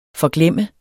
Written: forglemme

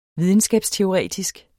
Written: videnskabsteoretisk

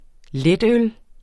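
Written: LETØL

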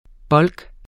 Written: bulk

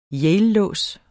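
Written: YALELÅS

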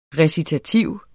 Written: recitativ

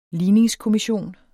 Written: ligningskommission

